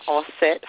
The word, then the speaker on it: Auset: My name is Queen Auset Haru. Auset is spelled A-U-S-E-T, Haru, H-E-R-U.